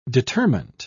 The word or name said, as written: determent